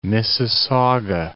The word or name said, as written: Mississauga